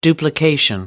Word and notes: duplication: American English

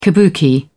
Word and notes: kabuki: British English

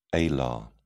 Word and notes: alar: British English

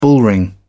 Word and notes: bullring: British English

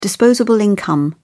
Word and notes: disposable income: British English

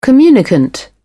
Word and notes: communicant: British English

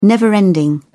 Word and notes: never-ending: British English

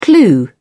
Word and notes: clue: British English

